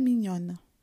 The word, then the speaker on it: mignon: É très mignonne. Eu acho ela muito fofa, mignonne. Se for algo fofo, mignon. É a mesma, a mesma pronúncia do filé mignon, Mignon é fofo.